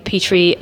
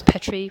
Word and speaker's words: Petrie: My name, Petrie, rhymes with tea tree. It is frequently mispronounced as Petrie, which is one of the most irritating things that happens to me on at least a weekly basis in my career as a performer.